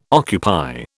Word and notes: occupy: British English

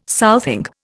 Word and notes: southing: Indian

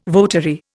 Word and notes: votary: Indian

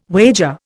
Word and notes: wager: Indian

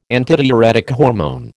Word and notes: antidiuretic hormone: American English

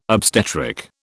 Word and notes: obstetric: British English

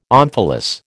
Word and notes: omphalos: American English

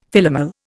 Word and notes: Philomel: Indian

